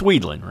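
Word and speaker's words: Wiedlin: How do you say that? – It's Wedlin, right? – Yeah, it is.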